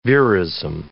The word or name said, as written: verism